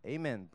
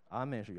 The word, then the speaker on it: Amen: 然后呢，这些摩西说百姓都要答应说阿们，都要说阿们，每一句都要说阿们，都要说阿们，太长了，我不能一个一个念。百姓都要说阿们，阿们的力量非常的大，你知道吗？阿们，阿们的力量非常的大。阿们就是一个希伯来话。 Amen 不是原来的发音，阿们是原来的发音，当然无所谓了。